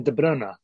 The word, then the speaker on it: De Bruyne: It's Kevin De Bruyne, according to Kevin De Bruyne, it is anyway.